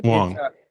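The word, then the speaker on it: Huang: And in fact, I saw on Twitter, I think I saw you were upset that China, so Jensen, is it Wang or Wong? How does he pronounce it? – Wong.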